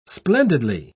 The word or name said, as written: splendidly